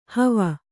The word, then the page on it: ಹವ: hava